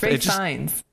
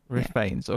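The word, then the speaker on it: Ralph Fiennes: And our main character, played by Rafe Fiennes, if I'm saying his name right, there was a debate about this because it's spelt Ralph. – Rafe Fiennes. – Rafe Fiennes. Okay. – Rhymes with safe signs.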